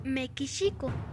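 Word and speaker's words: Mekishiko (メキシコ): En katakana, la pronunciación de México es Mekishiko.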